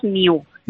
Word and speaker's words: Niu: Bueno, nosotros lo pronunciamos NIU. – NIU. Ah, que jugáis con el juego de palabras y lo hacemos como nuevo, ¿no? En inglés, pero se escribe sí. – con I. – Sí, exactamente. Ahora, el eslogan es no es nuevo, no es de ocasión, es new. – Es new. – Con I latina.